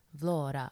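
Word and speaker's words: Vlorë: So not everything, but most things in Albania are spelt with an E at the end of them, with an E with like two I's on top of them, two I's being like two dots. And we've been told that it's pronounced Vlora.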